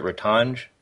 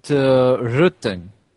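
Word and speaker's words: Rtanj: Is that how you pronounce it, Ratanj? – We call it Rutan. It is okay. Hard to pronounce in English, I understand.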